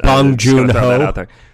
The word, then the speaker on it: Bong Joon-ho: I'm going to pronounce this wrong every time. – Isn't it Bong Joon-ho? – Bong Joon-ho, Bong Joon-ho's broadside against capitalism.